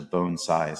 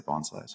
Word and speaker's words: bonsai: Any plant can be made into a bonsai, because the derivative term for bonsai, the two kanji that create the word bonsai, which is pronounced like bone, like your human bone, and sai, like, so bonsai is how you're supposed to say it. Mm-hmm. I happen to be from the South. I say bonsai.